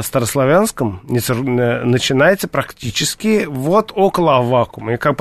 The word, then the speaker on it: Аввакума: Что касается протопопа Аввакума, я все-таки настаиваю, чтобы произносить именно с таким ударением.